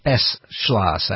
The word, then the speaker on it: Beschloss: But really, like Mussolini, Michael Beschloss is a, I think he says Beschloss. I should be kind to him and pronounce his name right, because I like the same courtesy.